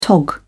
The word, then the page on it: tog: British English